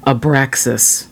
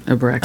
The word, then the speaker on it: Abraxas: And the cover was done by Santana in 1970 from the album Abraxas. That's the right way to say it, right? Abraxas. – I think so.